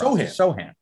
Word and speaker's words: Sohan: Sohan. I think I said it Sohan all through the draft. – Sohan. The emphasis is on so.